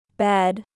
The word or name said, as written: Bed